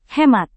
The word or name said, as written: hemat